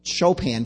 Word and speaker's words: Chopin: It's the flow of ebony and ivory with a piece by Chopin or Chopin for those of you who know how to say his name correctly, flowing in a concert.